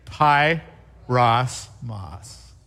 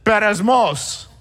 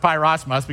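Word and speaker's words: peirasmos: Now, if you were a 21st century Greek speaker, you would probably pronounce it Beresmos. But we're not going to pronounce it that way. We're going to pronounce it Pyrosmos because nobody knows how to pronounce first century Greek anyway. So we're going to call it Pyrosmos, which means to test or to tempt.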